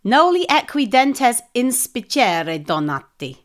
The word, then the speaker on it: Noli equi dentes inspicere donati: Well, it's obviously noli equi dentis ispiria donati. – I don't think that was bad. Noli equi dentes inspicere donati.